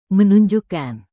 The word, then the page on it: menunjukkan: indonesian